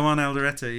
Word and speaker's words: Juan Alderete: Oh, yeah, Juan Alderete, yeah.